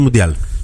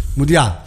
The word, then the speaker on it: Μουντιάλ: Νομίζω ότι είναι Μουντιάλ. – Μουντιάλ. – Ναι. – Ναι. Γιατί έχει σύμφωνο στο τέλος και τονίζεται. Μουντιάλ. – Γιατί νομίζω ότι είναι ισπανικό.